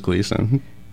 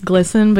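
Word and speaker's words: Glisan: I thought it was Gleason. – It's pronounced Glisson because it's named after the family Glisson. But a lot of people, everyone says Gleason, but, and people when they say, anyway. Glisson is the right way to say it, but I feel silly saying it because it feels pretentious, you know? – Right.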